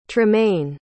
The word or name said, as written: tremaine